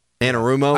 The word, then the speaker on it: Anarumo: Lou Amarillo, is that his name? – Anarumo? – I'm Aroma. Yeah, that's it.